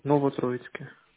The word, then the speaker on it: Новотроицк: Новотроицке.